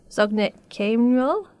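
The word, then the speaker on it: Zog nit keyn mol: Giacomo, you've chosen our first song for us, which is Zognit Kemrol. I may have pronounced that wrong, sorry. – That's pretty good. Good Yiddish. Well done.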